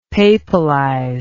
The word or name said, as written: papalize